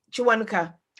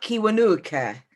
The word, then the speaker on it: Kiwanuka: K is Ch. – Chirabo. K-I. So you know the musician Chiwanuka? – Yeah. Oh! – He's not Kiwanuka.